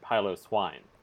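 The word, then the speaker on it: Piloswine: So I looked up the pronunciation, and according to the anime at least, it's Swinub and Pilo Swine.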